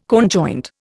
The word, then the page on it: conjoint: Indian